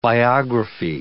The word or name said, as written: biographee